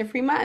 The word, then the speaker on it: frimas: Yeah, no, actually it's Frima. We don't pronounce the S in French, so it's Frima.